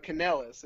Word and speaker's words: Kanellis: But Kanellis is how you pronounce it. Mm. So you're right on track. Just drop the O and add an E. So it's Kanellis.